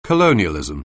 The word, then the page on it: colonialism: anglais